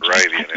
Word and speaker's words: Moravian: Moravian is how it's pronounced.